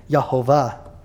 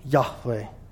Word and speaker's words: Yahweh: But scholars today are relatively settled that the closest proper pronunciation is probably Yahweh, as the consonants are connected with the Hebrew verb hayah, which means to be.